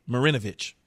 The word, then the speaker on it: Marinovich: Marinovich.